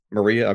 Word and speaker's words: MariaDB: And this ability to use a, I don't know if it's pronounced Maria or Maria. I never know how to pronounce it. Maria. Okay, thanks.